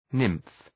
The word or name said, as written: nymph